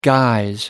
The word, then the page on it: guise: American English